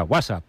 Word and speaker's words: WhatsApp: No, peor aún, con acento en la primera. ¿WhatsApp?